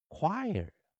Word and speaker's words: choir: 所以 o i r 它实际上前面的 o 没发音，后面的 i 去发出长元音。较特别的，ir 啊，前面的 ch 去念 choir，choir，choir，choir，好，所以这个啊 choir，所以很 or 去念。